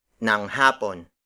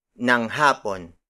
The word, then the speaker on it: ng hapon: In the afternoon, the time on the clock is followed by ng hapon. Ng hapon. Ng hapon. Ng hapon is spelled as ng space h a p o n.